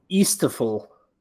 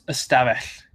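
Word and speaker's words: ystafell: I would like you to pronounce this one for me. It's Y-S. It begins with Y. Beautiful. Y-S-T-A. F-E-L-L. Y-S-T-A-F-E-L-L. – Easterful. – It's a good guess. It's Estavell.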